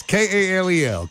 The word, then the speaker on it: Kal-El: And they told me how to pronounce it. It's K-A-L-E-L, Kal-El. And they said, yes, as in Superman's name.